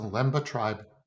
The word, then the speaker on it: Lemba: Am I saying that correctly? – Yes, the Lemba Tribe.